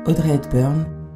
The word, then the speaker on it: Audrey Hepburn: On va écouter un texte qui a été lu à l'enterrement d'Audrey Hepburn, c'est ça? Ça se prononce comme ça? – Oui, que vous avez choisi.